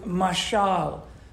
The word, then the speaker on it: mashal: And the word mashal, mashal, like Michal Benson, you know, mashal with an M-A sound, not the M-I.